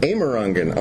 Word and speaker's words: Amerongen: Can I say it right? Amerongen? – Amerongen, okay. – Yeah. You said that's how I say it.